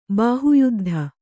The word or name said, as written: ಬಾಹುಯುದ್ಧ